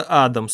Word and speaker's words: Адам: Это Адам, 100%, с ударением на первой букве.